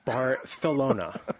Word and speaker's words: Barcelona: You need to pronounce Barcelona with that slight lisp. – Barcelona.